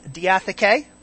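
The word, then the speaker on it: diatheke (διαθήκη): It is dia, D-I-A, T-H-E with an accent, K, E with an accent. It's pronounced diatheke.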